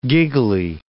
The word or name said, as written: giggly